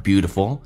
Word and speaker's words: beautiful: You don't have to compare yourself to others and never try to imitate someone just to look beautiful and be appreciated by other peopleBecause you have your own unique beauty. OK, 我们一起来学习如何让每个发音更加的精准。You are beautiful. 美式发音当中 T 趋向于发 D 这个发音 Beautiful.